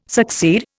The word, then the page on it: succeed: Indian